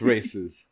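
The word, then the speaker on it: RAICES: Like, I've donated money to Raises Texas. Am I saying it right? It's Raises, or is it Races Texas? Because that's how it spells out, and it's kind of perfect. – Yeah, it's Races.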